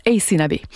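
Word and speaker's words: Aysanabee: Alors, je vous épelle son nom, c'est A-Y-S-A-N-A-B. Ça se prononce Ace Nabi.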